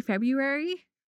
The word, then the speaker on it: February: Because if you just say February, you don't think there's an R. – Yeah. – Because we kind of make it super silent, which I'm sorry to everybody who is learning English and is like, why?